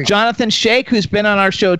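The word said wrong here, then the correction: Schaech: I don't know if that's how you pronounce his last name. – Shack. – Yeah. Jonathan Shack.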